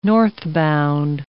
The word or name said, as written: northbound